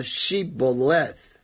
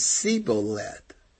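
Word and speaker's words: Shibboleth: They said unto them, "Say now, Shiboleth." And he said, "Siboleth," for he could not frame to pronounce it right.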